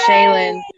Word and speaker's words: Shaylin: It is Shaylin. I've gotten so many like a lot of people say Sherilyn. I don't know why people put R in my name, but yeah.